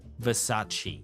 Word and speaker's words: Versace: Versace.